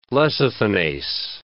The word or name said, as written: lecithinase